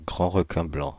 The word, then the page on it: grand requin blanc: /ɡʁɑ̃ ʁə.kɛ̃ blɑ̃/; Paris